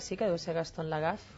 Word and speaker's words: Lagaffe: A més, té un motiu, que és el 50 aniversari del Sergi Grapes, Gaston Algafe, Gaston Lagaffe, no? Es diria en francès.